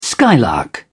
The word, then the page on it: skylark: British English